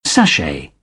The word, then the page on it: sashay: British English